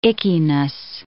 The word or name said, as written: ickiness